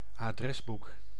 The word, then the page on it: annuaire: [a.nɥɛʁ]; France